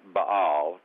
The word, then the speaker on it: Baal: Now, the Hebrew word Baal, or, and you said it correctly, Baal, just the Hebrew word, not the false god, but the Hebrew word Baal, actually means husband or marriage.